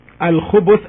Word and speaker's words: الخبث: الخبث as it's pronounced now by me, الخبث with the ba, with the letter ba taking a dhamma, as you can hear me, الخبث.